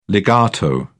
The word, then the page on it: legato: British English